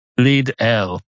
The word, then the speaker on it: Lidl: Some people pretend there's an extra vowel in there, as there should be. It's spelled L-I-D-L. Lidl. That's not a word in this country. But people pretend it is, so they say it's Lidl.